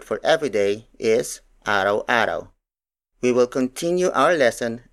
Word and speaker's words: araw-araw: For example, araw-araw, araw-araw, araw-araw. Araw-araw is spelled as A R A W hyphen A R A W.